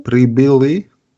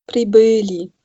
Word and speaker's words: прибыли: Прибыли. – Pribeli, genau. Also dieses Zeichen da zwischen B und L, dieses Ö, da wird jetzt die Betonung draufgelegt.